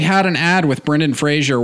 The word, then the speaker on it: Fraser: It's pronounced Fraser, not Fraser.